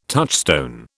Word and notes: touchstone: British English

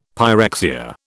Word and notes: pyrexia: British English

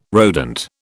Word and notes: rodent: British English

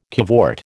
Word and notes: cavort: American English

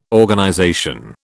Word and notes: Organization: British English

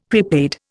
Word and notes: prepaid: Indian

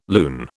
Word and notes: lune: British English

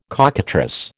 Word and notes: cockatrice: American English

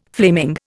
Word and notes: flaming: Indian

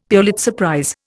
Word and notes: Pulitzer prize: Indian

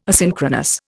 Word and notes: asynchronous: Indian

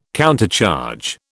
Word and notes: countercharge: British English

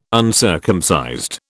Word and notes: uncircumcised: British English

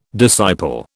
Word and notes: disciple: British English

